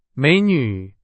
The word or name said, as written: 美女